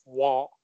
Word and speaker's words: Roy: It looks like Roy, but I know enough about hockey that it's Waugh.